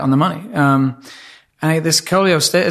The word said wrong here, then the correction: cholestasis: is that how you pronounce it? Coleostasis? – Sure. Cholestasis. – Cholestasis.